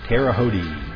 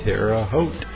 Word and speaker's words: Terre Haute: Terre Haute. Terre Haute. Some people that don't know how to pronounce it use all those different pronunciations, but it's Terre Haute.